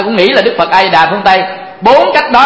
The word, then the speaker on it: A Di Đà: Như vậy thì chúng ta đọc A Di Đà Phật ta biết đó là Đức Phật A Di Đà ở phương Tây, chúng ta đọc là A Mi Đà Phật biết là Đức Phật A Di Đà ở phương Tây.